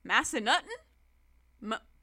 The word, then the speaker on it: Massanutten: I'm going to say this wrong. Massanutten? – I think that's how the locals would pronounce it.